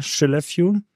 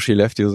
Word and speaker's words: Skellefteå: Okay, aber und was ist dann die Kapazität der Fabrik da in Skellefteå? Habe ich schon falsch ausgesprochen. – Einfach Skellefteå sagen, das ist schon.